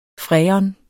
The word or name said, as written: freon